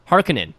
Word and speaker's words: Harkonnen: Baron Harkonnen. I can't remember how to pronounce his name. Harkonnen. That's it.